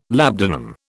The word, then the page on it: labdanum: British English